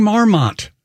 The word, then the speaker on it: marmot: And by the way, coming up next marmot? – Next week. – Stop saying marmot. It's marmot. – It's spelled with an O. – I know, but it is marmot, usually pronounced more casually.